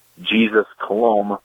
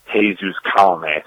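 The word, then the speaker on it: Jesús Colomé: I read it as Jesus Colomb, but it actually turned out to be pronounced Jesus Colomé.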